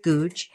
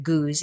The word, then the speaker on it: Goudge: And her name is Elizabeth Googe, G-O-U-D-G-E, Elizabeth Googe. They pronounce it Googe in England, but she is a British author that has been dead, I think, since the 60s.